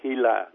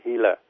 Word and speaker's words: Gila: Thì người ta khám phá là ở trong một cái con thằn lằn độc gọi là Hila, nó viết là G-I-L-A nhưng mà mình đọc là Hila.